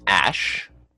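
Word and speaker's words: Æ: So the AE thing is pronounced Ash.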